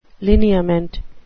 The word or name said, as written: lineament